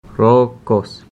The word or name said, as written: rookos